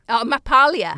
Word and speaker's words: mapalia: Mapalia. – Megalia. – Oh, it's M-A-P, sorry. I probably pronounced it properly. – Oh, M-A-P, right, okay.